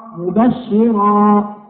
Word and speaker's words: مبشرا: وبالنسبة للمفتوحة وصلا ووقفا أيضا مثل كلمة مبشرا، الراء هكذا تنطق را مبشرا، ما نقول مبشرا، هذه الراء هنا مرققة.